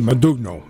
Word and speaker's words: Modugno: Am I saying that right? – Modugno. – Modugno. – Modugno. Yeah.